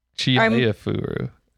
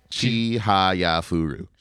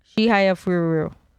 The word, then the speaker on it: Chihayafuru: Chihayafuru. Oh, I missed a syllable. – Yeah, you missed one syllable. – What was it? – Chihayafuru. – Chihayafuru.